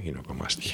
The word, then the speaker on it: ginecomastia: Doctores, ¿qué grado de satisfacción se produce en los en las personas que te deciden hacerse una ginecomastia? ¿Lo he dicho bien? Ginecomastia. – Ginecomastia. Sí, lo has dicho bien.